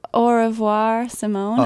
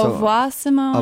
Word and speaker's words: Au Revoir Simone: Oh, it's Au Revoir Simone. – Oh, so Au Revoir Simone. – Au Revoir Simone. – Or however you want to say it, but it's AU REVOIR Simone.